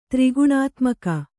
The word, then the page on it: ತ್ರಿಗುಣಾತ್ಮಕ: tri guṇātmaka